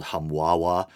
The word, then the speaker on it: Humbaba: Specifically, he was said to be the brother of Humbaba, kind of a funny word, sometimes pronounced or spelled Humwawa, even funnier, the monstrous guardian of the cedar forest in the Babylonian Epic of Gilgamesh.